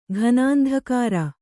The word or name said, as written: ಘನಾಂಧಕಾರ